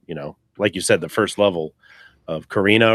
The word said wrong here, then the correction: Corneria: Corneria. – Yeah, Corneria.